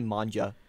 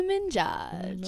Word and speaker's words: Minaj: My English teacher called Nicki Minaj Nicki Manja one time. – Nicki Minaj. – And I was like, I'm like, how do you say it that wrong?